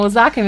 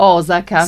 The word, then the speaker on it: Osaka: Ecco, brava, precisa, perché noi diciamo Osaka, invece si pronuncia Osaka.